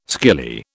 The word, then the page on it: skilly: British English